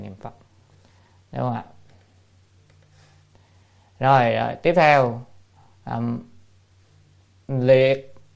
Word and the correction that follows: lệ: hay là chữ này đọc là lệ như sát giới trung thuyết.